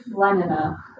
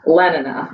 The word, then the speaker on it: Lenina: Lenina. – Lenina. – Yes. – Okay. – So we are introduced to Lenina, and she's actually a beta. – Mm-hmm. – And then with Lenina, she also has a conversation with Fanny.